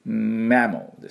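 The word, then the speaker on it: mammal: じゃあマモのとこね、発音いきたいと思いますが、これ M ですから、唇しっかり閉じてね、マモマモです。で、おみゃーのですね、マモで、そのままマンって閉じた後にすぐ L です、マモ。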